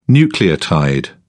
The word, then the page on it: nucleotide: British English